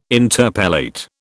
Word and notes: interpellate: British English

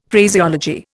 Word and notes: Phraseology: Indian

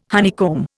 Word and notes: honeycomb: Indian

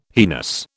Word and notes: heinous: British English

